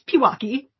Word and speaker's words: Pewaukee: It's Pewaukee. – Okay, Pewaukee.